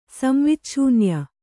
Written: ಸಂವಿಚ್ಛೂನ್ಯ

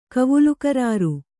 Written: ಕವುಲುಕರಾರು

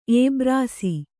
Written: ಎಬ್ರಾಸಿ

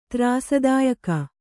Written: ತ್ರಾಸದಾಯಕ

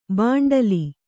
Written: ಭಂಡೀಲ

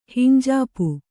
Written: ಹಿಂಜಾಪು

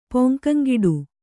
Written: ಪೊಂಕಂಗಿಡು

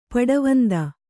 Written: ಪಡವಂದ

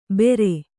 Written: ಬೇರೆ

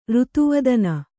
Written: ರುತುವದನ

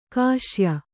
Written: ಕಸಾಯಿ

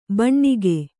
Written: ಭೂನಾಗ

